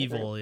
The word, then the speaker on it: EVOL: Was it Evo? I don't know how to say it. – Evil, yeah. – Oh, Evil. – Evil, yeah. – That group. – E-V-O-L.